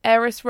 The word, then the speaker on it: Aeris: Aeris Roves. – Aeris, how do you spell that? – A-E-R-I-S.